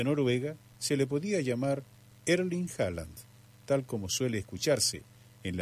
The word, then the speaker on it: Haaland: De manera aproximada, su nombre se pronuncia como Erling Haaland. La diferencia principal está en el apellido. En noruego la H inicial se aspira suavemente con un sonido similar a una J, suave, en español.